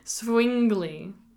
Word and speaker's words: Zwingli: Zwingli. I've been told that the W is pronounced as a V. – What? – So it's Zwingli.